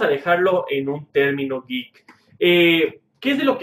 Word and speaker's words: geek: Antes que nada, vamos a, a ver qué es geek, ¿no? Y geek es pronunciado como wick.